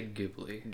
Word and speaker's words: Ghibli: Is it Ghibli or Ghibli? I always feel like I can't do it. – I say Ghibli. – Ghibli? Okay. I feel like I always say that wrong, and I apologize. – It's one of those you can pronounce either way.